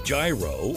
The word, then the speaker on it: gyro: How do you pronounce the word G-Y-R-O? Whether you say gyro or gyro, it doesn't matter.